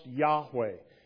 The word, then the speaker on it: Yahweh: In Hebrew, it's pronounced Yahweh.